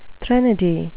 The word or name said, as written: threnody